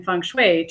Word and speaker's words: feng shui: Feng shui? Am I saying that right? – Yeah, it's feng shui.